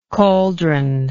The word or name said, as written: caldron